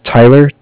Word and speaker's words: Tyler: My name is Tyler, T-Y-L-E-R. – Uh-huh. – And I'm calling with Cardiff. It's spelled C-A-R-D-I-F-F, as in Frank Frank.